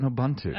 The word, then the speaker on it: Nobuntu: Nobuntu. I'm saying that right. – Yes. – All right, I nailed it. Okay. – Yes. – So tell me about Nobuntu.